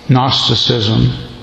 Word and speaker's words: Gnosticism: Gnosticism. You don't pronounce the G.